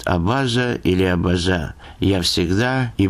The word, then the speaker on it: абаза: Ответ был однозначным – на третьем, то есть «абаза».